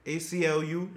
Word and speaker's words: ACLU: The ALCU... I said it right? American Civil Liberties. ACLU.